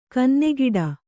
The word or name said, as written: ಕನ್ನೆಗಿಡ